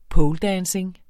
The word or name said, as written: poledancing